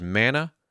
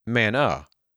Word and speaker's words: manna: For example, the small whitish substance mentioned in Exodus 16 and Numbers 11 that we know as manna is pronounced man and not manna.